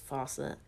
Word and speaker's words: Fawcett: Published by Fuck It Comics and DC Comics. I think I just pronounced the Fawcett. I just think it's Fawcett Comics.